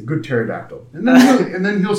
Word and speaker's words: pterodactyl: He goes, I'm a pterodactyl. What is a pterodactyl, not a pterodactyl? No, there's a P in the front. I said, the P is silent.